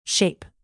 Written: shape

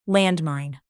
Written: landmine

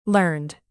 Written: learned

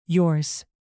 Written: yours

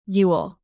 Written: yule

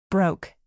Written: broke

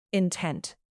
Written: intent

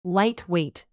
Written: lightweight

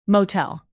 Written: motel

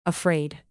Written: afraid

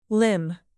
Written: limb

